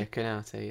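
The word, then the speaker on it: Konaté: And as Marley said, Liverpool, even with Virgil van Dijk and Gomez coming back into the team, and with the how do you say his name? Konate, is it? Konate? – Yeah, Konate, yeah.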